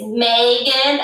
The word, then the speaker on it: Maegan: It's Maegan, M-A-E-G-A-N, and that's after my grandmother, whose middle name is May.